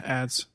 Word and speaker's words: adzes: It goes into like ads. Is that how you say it? The A D Z E S. – Yeah, ads.